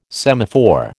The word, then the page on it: semaphore: American English